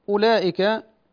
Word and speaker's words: أولئك: أيضا أولئك، الواو في أولئك التي بعد الهمزة لا تنطق، الواو مزيدة في الرسم، يعني لا أقول أولئك، هذا خطأ، الواو مزيدة في الرسم لا تنطق، يعني أنتقل من الهمزة إلى اللام، أولى ليس أولى، وهذا في كل المواضع في القرآن، هذا في كل المواضع في القرآن.